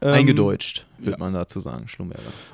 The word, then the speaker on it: Schlumberger: Ja, aber spannend, ich glaube, Schlumberger, ich habe gerade auch mal nachgeguckt, ist ja wirklich in Frankreich auch gegründet, wird sehr wahrscheinlich Schlumberger ausgesprochen. Ich wusste es so auch nicht, ich habe immer Schlumberger gedacht.